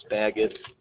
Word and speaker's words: Spagat: Fred Spaggett. – Can you spell your last name? – Spaggett. – Will you spell it? – S-P-A-G-A-T.